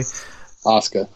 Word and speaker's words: Asuka: Asuka.